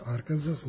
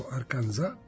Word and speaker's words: Arkansas: Odmah da rešimo Arkansas, sad neko u Americi ne izgovara Arkansas, nego Arkansas, za razliku od Kansas koji se izgovara Kansas.